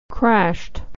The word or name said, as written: crashed